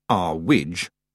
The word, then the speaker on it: Earwig: And Mrs. Earwig, pronounced R-Widge, at least by Mrs. Earwig,